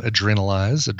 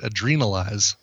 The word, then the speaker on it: Adrenalize: It's funny, when I was listening to this and telling my phone, Siri, when I was telling her to play this album, she pronounced it Adrenalize, like adrenal gland. – Huh. – But I think of it as adrenaline, Adrenalize.